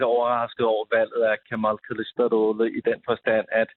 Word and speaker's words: Kılıçdaroğlu: men Kılıçdaroğlu.